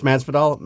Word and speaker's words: Masvidal: It is Masvidal.